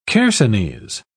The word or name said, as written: chersonese